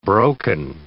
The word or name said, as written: broken